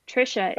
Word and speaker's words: Tricia: Tricia is spelled... – How do you spell Tricia? – You're right. T-R-I-C-I-A.